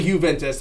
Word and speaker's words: Juventus: I know it's a J-U, but even if it was Jew, it's pronounced kind of both ways. It's primarily Juventus. That's how most people say it. But I've heard it the other way too, where it's like tomato, tomato, Juventus, Juventus. But it's Juventus.